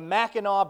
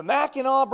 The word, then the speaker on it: Mackinac: 185 miles north of this church building is a place that I've been told is pronounced the Mackinac Bridge. Did I say it right? Whew! I guess I'm a Michigander now. I said it right. Okay. The Mackinac Bridge.